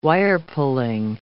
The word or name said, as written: wire-pulling